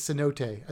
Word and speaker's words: cenote: I think it's Cenote. I think I just said that wrong. C notes. C notes is actually an email that goes out from the Florida Aquarium, but this is Cenote.